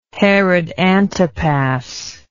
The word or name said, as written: herod antipas